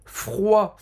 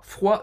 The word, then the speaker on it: froid: Então, les activités hivernales, en décembre, il fait très froid. – Froid, non prononcia au D no final.